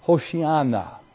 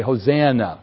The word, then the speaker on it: Hoshiana: The word "save us, Lord," in Hebrew is pronounced Hoshiana, or we kind of Gentile it up a bit. We say Hosanna, because we don't like Hoshiana.